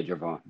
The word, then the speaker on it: Gervon: Am I saying it right, Gervon? – Yeah, Gervon.